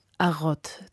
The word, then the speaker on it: Arod: September, da ist das Quatuor Arrot zu Gast gewesen. Zwar französisch ausgesprochen, verbirgt sich aber hinter dem Namen die Begeisterung für einen berühmten englischen Roman, nämlich den Herrn der Ringe von J.R.R. Tolkien. Arod, so heißt das Pferd von Elb Legolas, einem der Gefährten von Frodo, dem Ringträger.